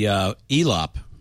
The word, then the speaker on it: Elop: Yeah, I actually think it's going to be ELOP, rhymes with flop.